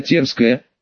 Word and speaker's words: Терская: «Меня зовут Ариадор, баронесса Стефания Терская», делая ударение на первом слоге фамилии, ответила она и после некоторого колебания осторожно вложила свои тонкие пальцы в мою ладонь.